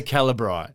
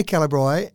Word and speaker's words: Calibri: Well, I say Calibri, and I was doing a radio interview, and the interviewer said, oh, is that how you meant to pronounce it? That's right. He said, I've always said Calibri. I went, oh, well, it could be Calibri or Calibri.